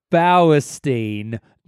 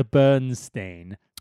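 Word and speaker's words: Bauerstein: This chapter is called Dr. Bowerstein, not Dr. Bernstein.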